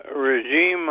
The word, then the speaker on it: Raheem: Yes, Raheem, I hope I pronounced your name right.